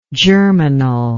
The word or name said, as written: germinally